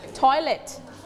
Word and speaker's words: toilet: สบู่นั่นเองนะคะสบู่ก้อนค่ะต่อไปโอเค what is this one what is it called that's right go four five six two three and one everyone say toilet one more time toilet don't forget to pronounce the t sound toilet okay thank you toilet ต่อไป what is it yeah.